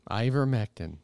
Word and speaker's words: ivermectin: Ivermectin, is that how you say it? – Ivermectin.